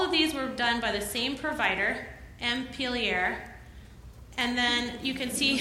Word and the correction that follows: Montpelier: So Montpelier, Vermont, yes.